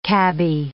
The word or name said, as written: cabby